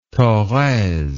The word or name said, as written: thorez